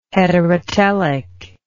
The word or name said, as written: heterotelic